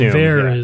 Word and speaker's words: haricot vert: Oh, that's how that word is pronounced. Now I know the haricot vert. – Haricot vert, right.